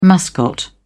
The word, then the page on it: mascot: British English